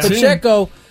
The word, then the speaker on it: Pacheco: Pacheco. Who actually had a really good game running the ball. He got the Chiefs within three. – Second down, goal to go at the one. Pacheco in the backfield.